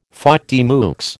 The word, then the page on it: faute de mieux: American English